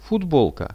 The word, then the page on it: futbolka: Russian